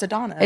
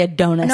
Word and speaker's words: Adonis: And it's also, I think it's pronounced Adonis. – No, it's Adonis. – It's definitely Adonis. – No, there's one N.